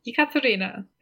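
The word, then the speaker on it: Ekaterina (Екатерина): She was named Grand Duchess, and they bestowed the name Catherine on her, which is pronounced in Russian, Ekaterina. I'm going to go with that.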